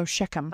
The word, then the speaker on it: Shechem: From what I know about Hebrew and Arabic, that would probably be a k sound. – Okay. So Shechem.